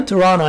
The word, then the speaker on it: Toronto: Chuano, allí se pronuncia así, Chuano.